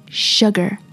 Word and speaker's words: sugar: 在这里要给大家正音一下，通常我会听到 sugar，标准的发音是 sugar， sugar， sugar。其实 sugar 一开始的发音有点像中文里舍不得的舍，sugar， sugar，而不是大树的树，sugar，不对，sugar， sugar， s u g a r， sugar， sugar。